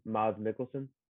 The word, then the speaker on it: Mads Mikkelsen: I don't know how to pronounce this guy's name. – Mads Mikkelsen? – Yes.